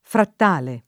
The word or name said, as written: frattale